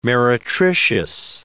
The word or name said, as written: meretricious